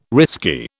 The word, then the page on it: risky: British English